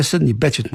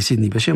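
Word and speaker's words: Sidney Bechet: Легендата на сопрано саксофона Сидни Беше, той е Сидни Бечет му казват, но френското произношение Сидни Беше, може би заради ход клуба в Париж, където той беше една от големите фигури.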